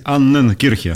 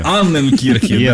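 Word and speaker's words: Анненкирхе: Замечательный Евгений Раскатов придет вам инструкцию, что правильно говорить Аннен Кирхи. – Аннен Кирхи, да.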